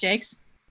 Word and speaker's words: Jacques: Is it actually pronounced Jakes? – It is.